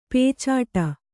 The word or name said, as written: ಪೀಚಾಡಿ